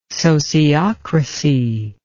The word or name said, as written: sociocracy